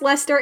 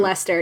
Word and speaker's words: Leicester: I'm assuming it's pronounced Lester. It's spelled Leicester, but it's, I know that in Britain it's mm-hmm Lester.